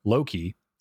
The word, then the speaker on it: Loki: because like Loki, L-O-K-I, would be spelled L-O-K-E and pronounced like Lok.